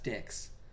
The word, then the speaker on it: DHX: Their studio got sold off to a company called DHX Dix. Pronounced Dix. DHX.